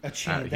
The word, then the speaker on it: Achane: Achane, that's how you pronounce his name.